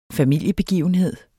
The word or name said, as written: familiebegivenhed